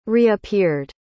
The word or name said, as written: reappeared